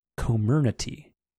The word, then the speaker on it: Comirnaty: So it is pronounced, or I mean, how the hell would I know? I don't work for Pfizer. Comirnaty.